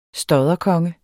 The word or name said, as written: stodderkonge